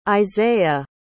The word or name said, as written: Izaiah